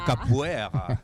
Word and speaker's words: capoeira: Capoeira.